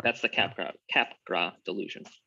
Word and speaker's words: Capgras: It is French in origin, so it's cap gras.